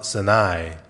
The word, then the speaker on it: Sinai: And then they arrive at Mount Sinai. – 예, 한국 발음으로 시내산인데 시나이 반도라고 말합니다.